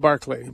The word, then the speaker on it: Barclay: It's Barclay's Daily Bible Study Guide, and that's spelled like Berkeley, but it's pronounced Barclay.